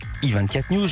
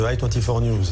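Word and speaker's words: i24NEWS: On va commencer par la présentation de I24 News, j'ai dit alias I24 News. Vous prononcez à l'anglaise, même sur les bandes annonces en français, la preuve.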